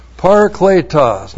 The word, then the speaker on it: Parakletos: Unless I go away, the Helper, and it's Parakletos, Parakletos, P-A-R-A-K-L, long E-T-O-S, Parakletos, not Comforter, Helper.